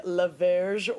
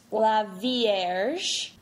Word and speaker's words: La Vierge: This particular bottle is part of the La Verge Wines of Desire collection, and La Verge is like French for the Virgin. – Let's start that over. It's pronounced La Vierge.